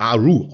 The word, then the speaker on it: Arru: Also, wenn ich es richtig ausspreche, Arthru, oder wie heißt das genau? – Es wird Arru ausgesprochen.